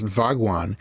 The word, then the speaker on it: Bhagwan: Bhagwan, actually, I think that would be pronounced Vagwan, is Sanskrit for more or less blessed one.